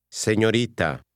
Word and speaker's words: señorita: Señorita. – Be careful with the R sound, which is different than in English.